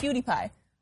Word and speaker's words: PewDiePie: Number 1 is, I'm not going to say this right, PewDiePie? – PewDiePie.